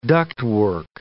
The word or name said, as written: ductwork